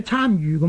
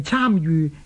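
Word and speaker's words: 參與: 即是干预或者参与咁解嘅，所以正確嘅读音呢係参与而唔係参与。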